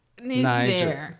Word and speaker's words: Niger: Yeah, but most likely near, let me say this correctly, because if I say the other word, it is offensive. It's Niger? – Niger.